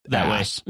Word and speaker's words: arse: Well, actually, arse used to be pronounced that ass. Way. – Yeah. It used to be, they would say it, they didn't pronounce the R.